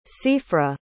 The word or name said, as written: seafra